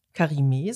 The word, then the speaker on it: Karimé: Ähm, mein Name ist Andrea Karime. Karime, sonst tut es mir weh, drei mhm. – Silben. – Es ist eigentlich nicht schwer, aber ich sage es manchmal gern dazu.